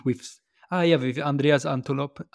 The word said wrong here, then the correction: Antonopoulos: I can't say his name Antonopoulos